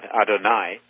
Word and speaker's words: Adonai: Adonai rhymes with El Shaddai.